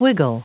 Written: wiggle

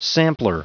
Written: sampler

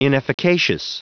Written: inefficacious

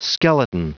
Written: skeleton

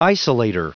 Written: isolator

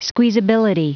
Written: squeezability

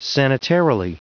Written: sanitarily

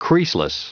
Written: creaseless

